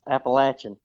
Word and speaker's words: Appalachian: Did I say that right? – Oh, you said it wrong. – Appalachia. – Way wrong. Yes, Appalachian. – Appalachian. – The best way to remember how to say it is if you call it Appalachian, I'll throw an apple at you.